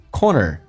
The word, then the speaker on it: corner: Corner, 美式发音需要重度卷舌 or 收圆，嘴巴收圆，整个舌身 corner 跟 of 有一个连音，叫做 corner of 角落的意思。